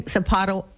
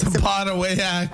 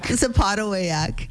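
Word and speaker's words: Sapotaweyak: We were saying Sapatawet. How did you say it? – Sapatawayak. – Sapatawayak.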